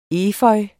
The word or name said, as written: efeu